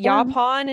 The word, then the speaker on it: Yaupon: Yaupon? Is that how it's pronounced? – Yeah. – Yep. Sure. Yaupon and dates.